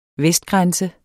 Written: vestenden

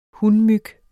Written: hunmyg

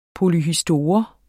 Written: polyhistor

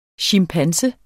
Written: chimpanse

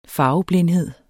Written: farveblindhed

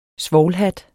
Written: svovlhat

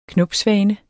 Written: knopsvane

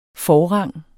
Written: forrang

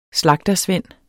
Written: slagtersvend

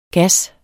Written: gas

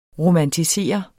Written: romantisere

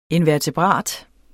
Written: invertebrat

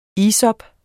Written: isop

